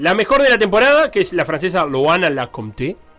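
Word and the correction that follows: Lecomte: O no se dicen la, palabra, la, sílaba final en francés. Le Comte. Le Comte.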